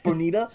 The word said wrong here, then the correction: Bayonetta: Bayonetta, man. Bayonetta. Yeah.